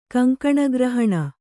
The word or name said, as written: ಕಂಕಣಗ್ರಹಣ